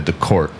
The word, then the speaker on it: Decorte: On the social media, you can find me on Facebook, Matt Decorte, DACORTE. If you want to know how I like to pronounce my last name, think of it like this. If you got a ticket, you take it to Decorte.